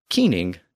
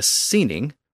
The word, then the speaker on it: cyning: And the standard French rules of pronunciation would suggest that if you put the C at the beginning of that word, C-Y-N-I-N-G, that it should be pronounced as an S, but it wasn't. I mean, England was ruled by a keening, not a seening, or cheening.